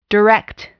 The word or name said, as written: direct